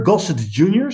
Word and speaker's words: Gossett Jr.: Die wordt al gespeeld door Louis Gossett Jr. Gossett Jr., zeg ik dat goed? Ja, toch? Ja.